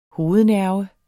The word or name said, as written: hovednerve